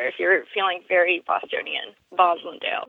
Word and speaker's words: Roslindale: Rosendale? – It's called Roslindale, but apparently the true Boston way to say it is Roslindale with a V in the front.